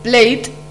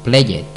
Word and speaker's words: played: For example, play, played, or finish, finished. – Es muy importante la pronunciación al añadir ed, ya que no podemos decir PLAYED, sino que se diría played.